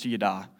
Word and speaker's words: yada: The Hebrew word for known in this verse is yada. It's pronounced yada, which means to intimately know someone.